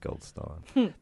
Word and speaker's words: Goldstein: He was here shooting, and I'm like, I grew up in Goldstein. He's like, actually, it's pronounced Goldstein.